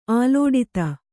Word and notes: ಆಲೋಡಿತ: Kannada

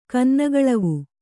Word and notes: ಕನ್ನಗಳವು: kannagaḷavu

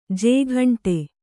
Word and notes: ಜೇಗಂಟೆ: jēgaṇṭe

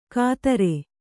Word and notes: ಕತ್ತರೆ: kattare

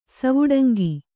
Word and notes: ಸವುಡಂಗಿ: kannada

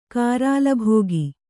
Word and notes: ಗರಲಭುಕ್ಕು: garala bhukku

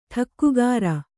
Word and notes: ಠಕ್ಕುಗಾರ: ṭhakkugāra

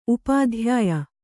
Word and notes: ಉಪಾಧ್ಯಾಯೆ: upādhyāye

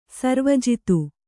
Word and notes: ಸರ್ವಜಿತ: sarva jita

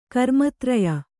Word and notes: ಕರ್ಮಧಾರಯ: kannada

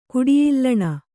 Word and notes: ಕುಡಿಯಿಲ್ಲಣ: Kannada

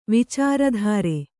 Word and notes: ವಿಚಾರದೂರ: vicāra dūra

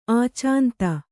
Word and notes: ಆಚಾಂತ: ācānta

